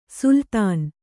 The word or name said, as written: ಸುಲ್ತಾನ್